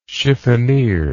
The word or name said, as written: chiffonniere